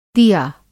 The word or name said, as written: दिआ